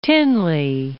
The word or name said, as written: Tinley Park